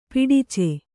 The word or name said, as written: ಪಡಚ